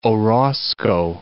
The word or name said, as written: Orozco